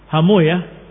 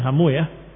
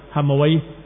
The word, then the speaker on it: Hamuyah: sebagian membacanya Hamuyah karena menghindari kalimat way, tapi aslinya adalah Hamuway, Rahaway, diakhiri dengan way, way, way. Tapi karena dikatakan wallahu ta'ala alam way itu adalah nama syaitan atau sesuatu yang jelek, maka para ahli hadis membacanya dengan dibalik harokatnya. Bukan rahu, bukan rohawai, tapi rahuya. Bukan hamuwai, tetapi hamuya. Ila akhirnya.